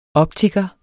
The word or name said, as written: optiker